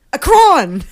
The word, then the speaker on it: Akron: Akron! It's Akron, Ohio. – Akron, Ohio.